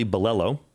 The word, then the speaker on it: Bilello: Now, this was originally sparked because I saw a tweet by one of our favorites, Charlie Bellello. And did I pronounce his last name correctly? – I think you did. Nice job.